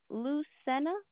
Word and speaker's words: Lucena: Soto, SOTO, Lucena, LUCENA.